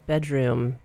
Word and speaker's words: bdrmm: That is a band from LA called Bedroom, but it's spelled BDRMM.